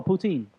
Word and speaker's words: poutine: Putin. You ever had Putin? – Russian sauce? – No, the sauce that's in Canada, they put on everything. Putin. – Oh, poutine. – Poutine. I said it wrong.